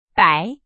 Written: 百

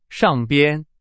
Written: 上边

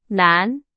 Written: 难

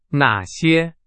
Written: 哪些

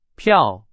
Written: 票

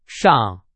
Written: 上

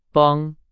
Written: 帮

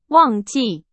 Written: 忘记

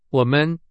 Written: 我们

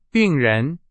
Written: 病人

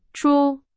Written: 出

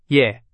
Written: 也

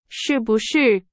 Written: 是不是

